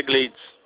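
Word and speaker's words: Gledys: É Glades. – É Glades? – É, o nome da filha dela é Glades.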